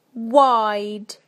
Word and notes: wide: British English

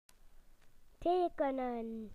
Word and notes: to draw: British English

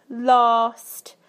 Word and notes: last: British English

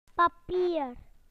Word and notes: paper: British English

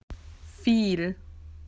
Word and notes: a lot: Australian English

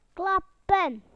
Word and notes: to clap: British English

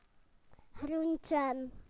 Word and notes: vegetables: British English

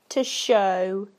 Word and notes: to show: British English

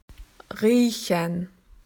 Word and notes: to smell: Australian English

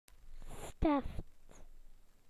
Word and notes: felt tip pen: British English

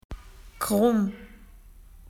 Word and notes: wiggly: Australian English

